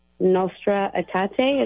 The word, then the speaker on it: Nostra Aetate: I just got done reading the Nostra Aetate.